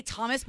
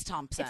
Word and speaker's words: Thompson: First, sometimes I say Thomas, but it's not Thomas. It's Thompson.